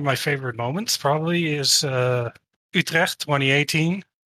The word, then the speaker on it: Utrecht: Utrecht. No, it has a hard G, which is pretty much impossible for Americans to say.